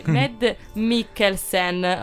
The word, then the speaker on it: Mads Mikkelsen: Ed è interpretato da Mads Mikkelsen. – Io e te con le pronunce andiamo proprio sparati. – Mi sono impapinata con le K, quindi Mads Mikkelsen. Ok, così è giusto.